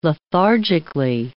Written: lethargically

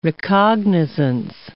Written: recognizance

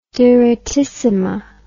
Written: direttissima

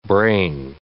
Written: brain

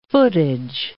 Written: footage

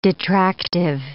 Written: detractive

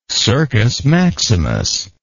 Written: circus maximus

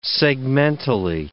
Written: segmentally

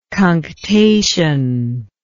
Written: cunctation

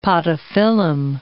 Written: podophyllum

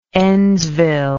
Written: endsville

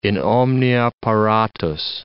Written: in omnia paratus

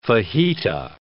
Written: fajita

